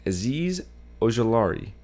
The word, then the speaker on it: Azeez Ojulari: I have them drafting Azeez Ojulari.